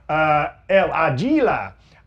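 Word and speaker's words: El Águila: Oh, like it's a hard G on that? Okay. Thank you, Mac Lopez. El Aguila.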